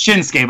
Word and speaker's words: Shinsuke: It's Shinsuke, but I appreciate you calling whatever. – Me back for how long I said it incorrectly. – Well, you know, technically. Oh, fuck you. 5 points. If you were purposely trying to emphasize something, you could say each syllable, but yeah, just so you know.